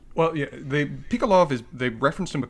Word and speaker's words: Pikalov: Uh, uh, they, they, they, there's so many weird pronunciations, but I think it's Pikolov based on the real guy. Pikolov? Pikalov. P I K A L O V. Pikolov.